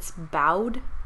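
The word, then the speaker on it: Bowdlerized: Bod, but it's bowed. Bowed. – Her name is Sally Bowles. – There's no L before the. There's no L before the D.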